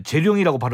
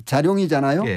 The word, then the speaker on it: 재룡: 재룡이란 말은 이제 자룡이잖아요. – 예. – 근데 요 자에 이 모음이 들어 있어요. 이 오가 요 발음이잖아요. – 아, 그렇죠. 예. – 예, 그럼 이 모음 역행동화가 일어나서 재룡이가 된 거예요.